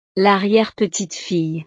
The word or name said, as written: l'arriere-petite-fille